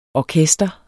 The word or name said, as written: orkester